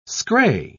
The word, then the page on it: scray: British English